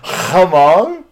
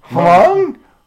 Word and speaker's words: Hmong: Hmong? – Hmong. – Hmong? – Pretend like it's M-U-N-G. – Hmong? – Hmong, the H Hmong? Is silent.